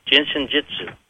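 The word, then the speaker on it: Jin Shin Jyutsu: It's J-I-N-S-H-I-N and then J-Y-U-T-S-U, pronounced Jinsu. I'm sorry, Jinsen Jitsu.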